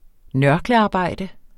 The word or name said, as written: nørklearbejde